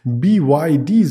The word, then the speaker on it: BYD: Das ist BYD, so spricht man es amerikanisch auch aus.